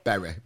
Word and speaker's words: Bury: I said Bury. Is it Bury or Berry? – Berry, Bury. I think if you're from Yorkshire like us, it's Bury.